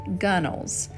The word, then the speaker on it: gunwales: Before I begin reading the poem itself, I'd like to introduce you to a word spelled G-U-N-W-A-L-E-S, and it's pronounced gunnels, and it refers to the upper edge of the side of a boat.